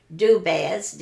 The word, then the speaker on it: Dubaz: Beverly, B-E-V-E-R-L-Y, Dubaz, D-U-B-A-Z, Romeo, R-O-M-E-O.